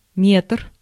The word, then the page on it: meter: /ˈmeːtɛr/